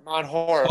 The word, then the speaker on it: Mount Horeb: It's called, it was in Mount Herebold, H-O-R-E-B. Is how it's pronounced. – H-O-R-B-B. – Corp? H-O-R-E-B. It's in Wisconsin, and it's called, it's a swamp. – Mount Horeb. – Okay, Mount Horeb. Mount Horeb, Wisconsin.